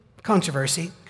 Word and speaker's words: controversy: Josh Ross is doing a series there once a month on a topic of some controversy, that's the way you pronounce it, by the way,